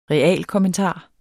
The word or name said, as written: REALKOMMENTAREN